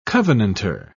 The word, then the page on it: covenantor: British English